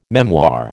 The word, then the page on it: memoir: American English